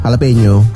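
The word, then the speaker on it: jalapeño: Jalapeno. – Hah? Bacanya gitu ya? – Iya, jalapeno. – Kok tulisannya kan jala? – Kan kalau orang Meksiko, orang Latin ngomongnya gitu. J jadi H.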